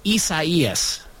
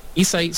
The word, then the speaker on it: Isaias: It's spelled similar to Isaiah, but it's pronounced Isaias.